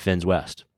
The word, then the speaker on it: Finns West: Finns West. Yeah, that's right. Two N's there.